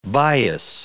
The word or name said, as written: bias